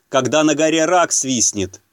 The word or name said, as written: когда на горе рак свистенет